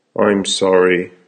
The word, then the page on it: I'm sorry: American English